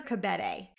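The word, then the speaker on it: Kebede: The day that we got to unwind Lila Khadibi, I believe, hopefully I'm not saying her name wrong, as the first Black face of Estee Lauder. – Oh, Leah? – Leah Khadibi, is it? – It's Leah Khabede.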